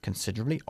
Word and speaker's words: considerably: Considerably, obviamente, significa... – considerablemente. – Muy bien, pero ojo con la pronunciación. No decimos considerably, sino considerably. La E de en medio se omite, ¿ok? – Considerably.